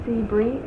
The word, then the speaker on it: Sebree: Okay, Karen, K-A-R-E-N, maiden name is Sebree, S-E-B-R-E-E, and last name is Barnes, B-A-R-N-E-S.